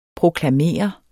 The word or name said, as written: proklamerende